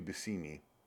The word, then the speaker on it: Buscemi: It's Steve Buscemi is not even actually Steve Buscemi. It's Steve Buscemi. That's actually how he says it. – Right. – But nobody gives a fuck. – Well, you're Buscemi and you're going to accept it.